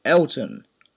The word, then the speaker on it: Elton: My name is Elton.